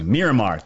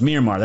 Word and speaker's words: Myanmar: Myanmar, thank you. Thank you so much. Myanmar, that's so much better than the way I pronounced it.